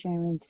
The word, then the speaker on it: Cheryn: My name is Sharon, C-H-E-R-Y-N, and my last name is Jarvis, J-A-R-V-I-S.